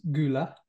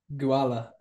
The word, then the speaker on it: gula: So instead of gula, it became guala, and finally adopting the word koala.